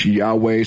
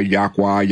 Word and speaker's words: Yahweh: But we find it as we study the manual, which is the scriptures, that it's written in the Hebrew scriptures that our God's name is Yod-Heh-Vav-Heh, which is pronounced Yahweh. Some people say Yahweh, Yahuwah, Yahuwah.